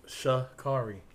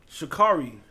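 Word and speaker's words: Sha'Carri: Shakari. – Shakari.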